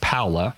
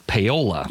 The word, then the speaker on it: Paola: I'd had some people tell me, and these were natives who told me that it was pronounced Paola.